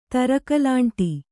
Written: ತರಕಲಾಂಟಿ

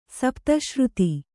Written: ಸಪ್ತಶ್ರುತಿ

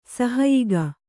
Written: ಸಹಾಯಕ

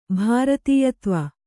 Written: ಭೃತ್ಯತ್ವ

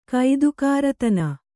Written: ಕೈದುಕಾರತನ

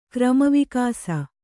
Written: ಕ್ರಮವಿಕಾಸ

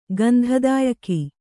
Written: ಗಂಧದಾಯಕಿ

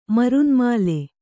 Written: ಮರುನ್ಮಾಲೆ

